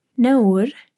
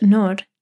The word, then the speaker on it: Naur: Naur, sometimes pronounced Nor, literally refers to something shameful or taboo.